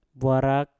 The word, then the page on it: bwarak: Mpur